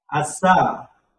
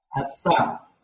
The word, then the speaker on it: 아싸: Last syllable you have to pronounce strongly, sa, asa.